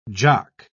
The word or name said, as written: jock